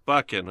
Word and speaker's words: Buchan: Is it Buchan or Buchan? Yeah. Buchan. Okay, excellent.